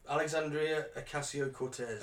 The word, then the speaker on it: Alexandria Ocasio-Cortez: Alexandria Ocasio-Cortez.